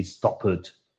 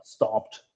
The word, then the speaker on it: stopped: Because ending with a T sound like worked or stopped, it's not natural. But the correct way to say those words are stopped and worked.